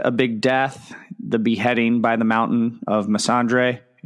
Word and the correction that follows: Missandei: I'm sure I'm pronouncing that incorrectly. – I think there's no R. It's Missandei.